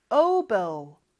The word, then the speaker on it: oboe: 영어로는 oboe, oboe, oboe라고 하지 않아요. oboe.